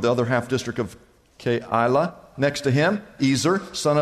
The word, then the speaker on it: Keilah: There's three syllables there. Keilah carried out repairs for his district.